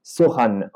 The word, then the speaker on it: Sochan: Ça s'écrit Sochan, ça, ça se dit Sohan.